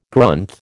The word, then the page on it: Grunth: American English